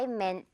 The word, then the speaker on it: meant: 여기 부분 meant라고 발음하는 거 신경 쓰면서요. 듣고 따라해 주세요. I meant to call you.